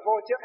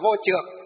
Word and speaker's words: vô trước: Vô trước hay còn gọi là vô trược. Trước còn chữ Hán, còn có chỗ còn gì đọc là trược. Trước cũng được, trược cũng được.